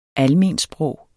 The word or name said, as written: almensprog